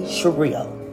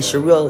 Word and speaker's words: Shireal: DJ Shireel. And Shireel is spelled S-H-I-R-E-A-L.